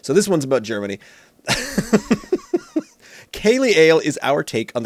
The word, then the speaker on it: Ceilidh: I love the fact that you pronounced it lovely, Kaylee, because earlier on when you sent that text, it auto-corrected to Ceiling Lagerdale. – Oh, did I? Didn't even notice it because Ceilidh, Kaylee, Kaylee.